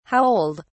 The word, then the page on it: how old: British English